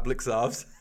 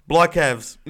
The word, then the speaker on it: Blicavs: You're like, Blycavs. No, you're like, it's not Blycavs because I never hear BT say, oh, and Blycavs got the ball.